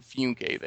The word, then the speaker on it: Funke: So it was driving me crazy that it wasn't Funke there.